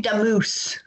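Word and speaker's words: Damus: It stars Corbin Allard as a high school student whose recently deceased best friend, played by Mike Damas, returns to Earth as his guardian angel. My pronunciation will never get old. – It's actually Damoose.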